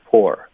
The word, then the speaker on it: poor: OK，他们的拼写有点不一样，实际上他们的发音是一模一样的，pour 就是泼水，我们就叫 pour。 Poor，然后就是贫穷是吗？就 P-O-O-R，它的发音也就是 poor， it's the same thing，没有区别。